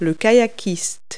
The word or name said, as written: kayaker